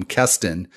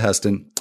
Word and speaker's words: Heston: And I've got to be real careful here with the pronunciation because I think already I've called him Keston. Not on the air yet, but he's not a Keston. He's a Heston.